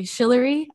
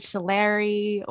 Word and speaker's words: Shillery: And yeah, so my name is actually Shillery. I've heard Shillary or just, you know, just crazy pronunciations, but it's Shillery. So just think of Hillary with an S.